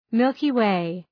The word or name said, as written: Milky Way